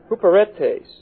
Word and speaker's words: huperetes: The word ministers in verse 1 is the word huperetes. Huperetes. H-U-P-E-R-E-T-E-S. Long E here at the end.